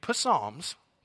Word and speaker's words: Psalms: But as you read the lyrics in Psalms, and I'm saying that intentionally, it's actually the P is silent. It's a weird thing about English.